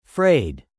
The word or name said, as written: frayed